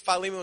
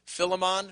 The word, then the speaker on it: Philemon: Some people, like my dear wife, pronounce it Philemon. Who pronounces it Philemon? Just be honest. There we are. We have some Philemonians in the house. I say Philemon. You say Philemon. We're referring to the same thing.